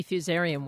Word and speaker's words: Fusarium: That would be Fusarium wilt.